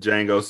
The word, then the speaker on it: Django: And Django says, "It's silent, hillbilly." – The D is silent, hillbilly.